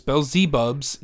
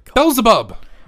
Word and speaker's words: Beelzebub: But it is the cemetery that is most feared, for they say it is Beelzebub's gate. Another word for Satan. Lucifer. – Again. – The voice. – Butchering pronunciations. – Beelzebub.